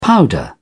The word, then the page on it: powder: British English